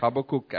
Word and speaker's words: Habakkuk: I pronounce Habakkuk accurately, not like the American.